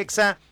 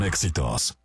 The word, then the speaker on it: Exa: Nosotros vamos a seguir con más y en todas partes Ponte Exa 99.7. – No se dicen éxitos, se dice Exa.